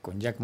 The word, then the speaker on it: JAC: Jack Motors, que se escribe, por cierto, JAC, así es como se escribe Jack.